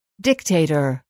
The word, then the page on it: dictator: díkteitər